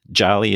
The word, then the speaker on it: Jarlie: Jarlie is pronounced very close to Jolly, and it means powerful in Swahili.